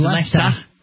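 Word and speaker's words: Leicester: That's the word the Americans always find tricky to say over here. – It's Lester. – It's Lester.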